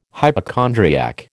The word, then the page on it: hypochondriac: American English